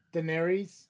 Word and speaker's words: Daenerys: Daenerys or whatever.